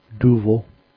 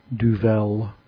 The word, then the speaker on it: Duvel: So, yeah, that's the right pronunciation, Duvel, not Duvel, as I sometimes hear it.